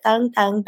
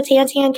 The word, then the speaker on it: Tan Tan: So it's Thung Thung. In Vietnamese, we pronounce it Thung Thung. But at the restaurant, when I pick up the phone, I'll be like, hello, Tan Tan Cafe, how can I help you? So it's totally okay to call it Tan Tan, but traditionally it's Thung Thung, and it means new, new.